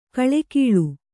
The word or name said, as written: ಘಳಘಳ